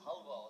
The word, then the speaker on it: Halva: zu einem weiteren Klassiker der türkischen Küche und zwar, es steht Helva drauf, aber ich weiß, dass es Halva ausgesprochen ist.